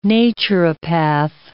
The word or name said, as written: naturopath